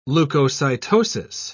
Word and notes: leukocytosis: British English

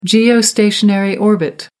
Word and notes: geostationary orbit: American English

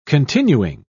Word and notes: continuing: British English

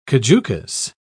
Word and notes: caducous: British English

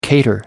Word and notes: cater: American English

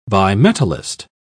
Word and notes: bimetallist: British English

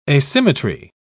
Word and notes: asymmetry: British English

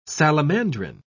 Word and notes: salamandrine: British English

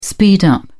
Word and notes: speed up: British English

